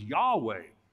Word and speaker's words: Yahweh: And that relationship grows over time from the Creator God, known as Elohim, to the covenant Lord, known as, well, we typically pronounce the Tetragrammaton as Yahweh or Yahweh Elohim.